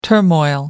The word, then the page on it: turmoil: American English